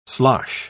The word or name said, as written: slosh